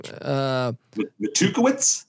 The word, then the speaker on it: Matukewicz: So, how do I pronounce this game? His name, Matukowicz.